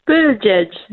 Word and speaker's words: Buttigieg: Buttigieg. It's like saying edge twice.